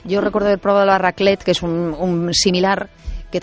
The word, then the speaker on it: raclette: Sí, sí, la raclette.